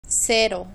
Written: cero

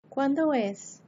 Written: cuándo es

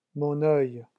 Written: mon oeil

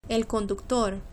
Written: el conductor

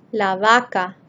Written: la vacca

